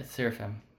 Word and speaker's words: seraphim: and is it pronounced a seraphim? – Yeah, seraphim.